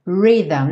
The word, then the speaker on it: rhythm: Rhythm. Não se assuste com a escrita dessa palavra. A pronúncia é rhythm. Rhythm, que é o ritmo.